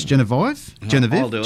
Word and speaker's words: Jennavive: I spelled it J-E-N-N-A-V-I-V-E. That's Genevieve.